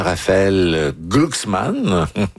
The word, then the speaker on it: Raphaël Glucksmann: Et Ségolène Royal a lancé dimanche une première salve contre le candidat Raphaël Glucksmann. Question à ce moment-là. Content de la prononciation. – Oui, bravo.